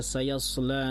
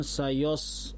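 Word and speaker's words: sayas (سَيَصْلَىٰ): Ah, ini juga hati-hati ketika kita baca sayasla narun zata lahab. Ya, bacanya sayas, jangan sayos, sayasla narun. Karena sok tebal jadi ya tebal. Ah. Clear ya? Seharusnya saya ya tetap tipis, sok baru tebal.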